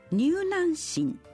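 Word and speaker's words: 柔軟心: 柔軟な心と書いて入軟心と読みま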